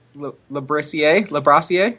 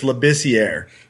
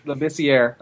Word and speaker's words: Labissier: There's no R right after the B. I believe it's Labissier. – Labissier, okay.